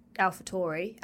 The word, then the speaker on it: AlphaTauri: So it's Tauri, but I think it is, if you were saying it with an English accent, it's AlphaTauri, as in Taurus.